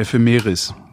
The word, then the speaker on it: Ephemeris: TPE, e, The Photographer's äh, F, ach, ach, FM, F, ja, was, wie, wie spricht man das richtig aus? Ich weiß es nicht, aber es heißt, äh, äh, wie heißt denn das nochmal? Erscheinung, ne? Äh, The Photographer's Ephemeras. – Äh, Ephemeris, ja.